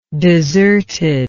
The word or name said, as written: desertedness